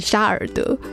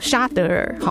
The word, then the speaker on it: 沙德尔: 好，大家都在关注这个台风的动态哦，中台沙德尔、哦、常常会念错，因为不熟悉这个名字，对，就会一直想要把它念成沙尔德，没有，它叫沙德尔、哦、中台沙德尔呢，现在还是以每小时 21 公里的速度朝着西北来前进哦。